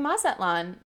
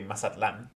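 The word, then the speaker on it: Mazatlán: Or, like, I was supposed to be in Mexico earlier this summer, you know, exploring Mazatlan. Is that how I say it? Is that the right way to say it? – I mean, it's actually Mazatlan.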